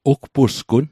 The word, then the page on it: ugpusgun: Mi'gmaq